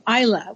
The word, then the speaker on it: Islay: that there is a distillery on the island of Isla, which is spelled Islay, I-S-L-A-Y, but it's Isla, and that's in the Hebrides.